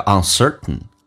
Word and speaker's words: uncertain: T 跟 N 在一起，在口语化当中可以 T 不发音，叫 uncertain.